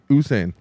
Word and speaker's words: Usain: raced with Usain Bolt. Yes. There's no H. – Like you have no idea who he's talking about if he says Usain Bolt.